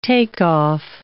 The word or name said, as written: takeoff